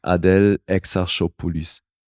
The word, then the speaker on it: Adèle Exarchopoulos: Da, ja, die hat einen fiesen Namen, weil das ist halt ein griechischer Name, der französisch ausgesprochen wird. – Und, hast du es drauf? – Adèle Exarchopoulos.